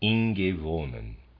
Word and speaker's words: Ingäwonen: Readers aside, Ingäwonen, excuse me, Ingäwonen, I'm going to spell that, it's I N G A umlaut W O N E N, Ingäwonen.